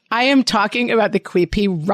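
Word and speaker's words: quipu: And you talked about, I believe it's the Quipi, is that how it's pronounced? – Oh, yeah.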